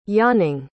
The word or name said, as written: yawning